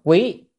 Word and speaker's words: quý: Bây giờ chúng ta đến cái mục tiếp theo, cái mục này, cái chữ này đọc là quý, quý nhị.